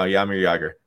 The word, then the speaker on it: Jaromír Jágr: You don't pronounce the J's, right? – No, Jaromír Jágr.